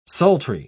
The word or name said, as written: sultry